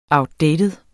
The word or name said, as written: outdated